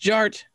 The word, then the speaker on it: Jart: The D is silent, so it's just Jart.